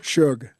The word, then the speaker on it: Schug: When Reverend Philip, is it pronounced Shug? – Shug. – S-H-U-G. – S-C-H-U-G.